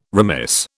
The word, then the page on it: remiss: British English